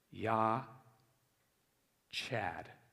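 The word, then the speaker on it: Yah Chad (יחד): The word for together is Yah Chad, but it's not Chad, okay? It's more like cod, okay?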